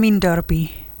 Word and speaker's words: Mien Dorpie: Plaatselijk belang Nieuw-Leuzen, afgekort tot PBN, heeft tijdens de algemene ledenvergadering het nieuwe dorpsambitieplan Nieuw-Leuzen, Mien Dorpie aangeboden. Zeg ik dat goed? – Nieuw-Leuzen, Mien Dorpie. – Dat is beter.